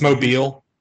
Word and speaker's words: Mobile: Tentative for the June pay-per-view show from Mobile, Alabama will include a bikini contest. – It's Mobile. – Mobile, whatever.